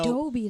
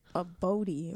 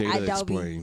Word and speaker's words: Adobe: Adobe, that's how you say it? – Adobe? Oh, okay. Is that, yeah. How you said it? – I said Abody or something.